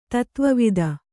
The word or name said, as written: ತತ್ವವೇತ್ತ